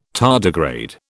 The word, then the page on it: tardigrade: British English